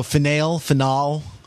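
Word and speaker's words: F'nale: though I looked at IMDb and the character is pronounced is spelled F apostrophe N A L E. So Finale.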